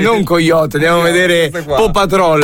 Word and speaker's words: Paw Patrol: Questo non so neanche di che ho portato mia figlia al cinema a vedere Paw Patrol. Come si pronuncia? Paw Patrol. – Si dice Paw Patrol.